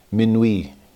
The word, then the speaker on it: Minuit: It begins with midnight. Minuit is how it's said. Minuit, chrétien, c'est l'heure solennelle où l'homme Dieu descendit jusqu'à nous.